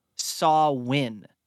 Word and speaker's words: Samhain: But the actual roots of Halloween come from a Celtic festival pronounced Samhain.